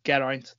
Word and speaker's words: Geraint: And my own personal account is Geraint. That's spelled G-E-R-A with a 1-N-T.